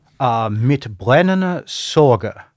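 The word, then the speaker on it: Mit brennender Sorge: Mit brennende Sorge is, I believe, the proper pronunciation.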